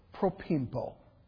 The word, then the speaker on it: propempo: It is the word propimpo. If we were to spell it in English, we would spell it P-R-O-P-E-M-P-O.